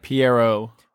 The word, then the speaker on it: Pierrot: Pierrot.